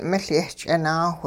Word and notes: miL-yehch'inaawh: hupa

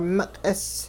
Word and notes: miq'is: Hupa

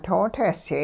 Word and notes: too-tehsyay: hupa

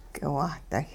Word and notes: k'iwahday': hupa

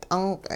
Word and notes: t'unq': Hupa

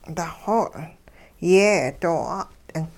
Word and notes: daxoo yeh doo 'at'ing: hupa

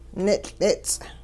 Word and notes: nitL'its': Hupa

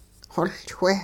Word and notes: xoLchweh: hupa

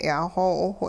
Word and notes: yaaxoo'awh: hupa

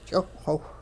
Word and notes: ch'iwhxiwh: Hupa

